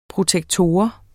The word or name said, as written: protektor